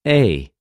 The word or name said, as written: εἰ